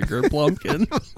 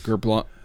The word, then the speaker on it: Gerblunking: Not a Gerblumpkin. Gerblunking. – Gerblunky? – Samsonite. – I was way off. – Gerblunking. – Let me write it. Hold on. Let me write it. – I'll spell it for you. – Go for it. – G-E-R. – Mm-hmm. – B-L. – Mm-hmm. – U-N-K-I-N-G. Gerblunking.